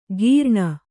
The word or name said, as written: ಕೀರ್ಣ